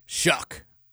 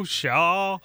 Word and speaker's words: Shuck: Say it again for me. – Shuck. – Like S-H-U-C-K. – That's how it's pronounced. – Okay. Tyler Shuck. I brought that up on KRC this morning because they were like, Shaw. And I was like, no, I think it's Shuck.